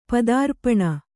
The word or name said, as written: ಪದಾರ್ಪಣ